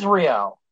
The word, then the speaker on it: Ryo: It is Rio. – Rio. – No, I'm not saying Rio. I'm saying Rio. – R-Y-O. – I thought it was R-E-Y-O. – No. – Is it R-Y-O? – Yeah, Rio. R-Y-O, okay.